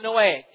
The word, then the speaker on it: Noahic: The first of these is the Noahic, N-O-A-H-I-C, if you want to know how to spell it, Noahic covenant.